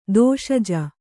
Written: ದೋಷಜ

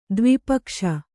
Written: ದ್ವಿಪಕ್ಷ

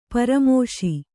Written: ಪರಾಮೋಶಿ

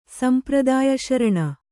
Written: ಸಂಪ್ರದಾಯಶರಣೆ

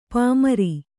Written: ಪಾಮರ

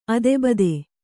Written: ಅದೆಬದೆ

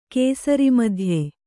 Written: ಕೇಸರಿಮಧ್ಯೆ

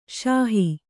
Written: ಸಾಹು

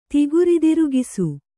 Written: ತಿಗುರಿದಿರುಗಿಸು

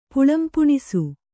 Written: ಪುಳ್ಳುಂಪುಣಿಸೆ

